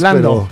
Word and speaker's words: Landau: Exige la renuncia de Marco Rubio y de Christopher Landau, o Lando, no sé cómo se pronuncia en inglés. – Lando, pero es como es un apellido alemán, confunde. Uh-huh.